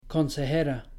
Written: consejera

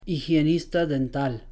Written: higienista dental

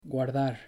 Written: guardar

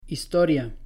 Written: historia

